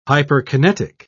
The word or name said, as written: hyperkinetic